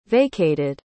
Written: vacated

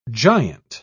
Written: giant

